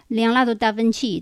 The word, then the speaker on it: Leonardo da Vinci: 那从小我在学画的时候，一直以为达芬奇嘛就是达芬奇的发音喽。当然我们是音译嘛。结果在美国艺术课堂时候，我发现没有人懂。那他只是说在名前姓在后，外国人才懂。然然后这个英语里面和意大利语，它就叫 Leonardo da Vinci，这是一个意意大利语的发音。